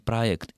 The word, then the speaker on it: проект: Мы не произносим J, и, соответственно, вслед за написанием по буквенным, транслитерированным, мы произносим проект.